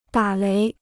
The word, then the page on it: 打雷: dǎ léi